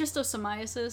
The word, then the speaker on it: schistosomiasis: It's schistosomiasis.